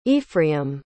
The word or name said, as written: ephraim